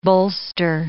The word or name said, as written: bolster